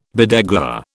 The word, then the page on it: bedeguar: British English